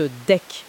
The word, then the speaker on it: DEC: Et il y a aussi une petite spécificité française assez intéressante, c'est le mémoire du DEC, on prononce DEC, le diplôme d'expertise comptable.